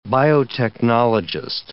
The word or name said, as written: biotechnologist